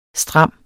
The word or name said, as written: stram